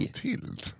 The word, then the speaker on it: Clotilde: Clotild. Minns ni stiffa Clotilde? Uttalas Clotild.